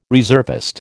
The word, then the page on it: reservist: American English